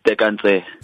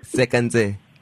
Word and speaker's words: Tseka Ntse: Did I pronounce it right? – Yeah, it's Tseka Ntse. – Tseka Ntse.